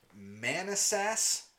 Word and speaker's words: Manassas: This happened in Manassas, Virginia. I'm going to just spell it for you. M-A-N-A-S-S-A-S.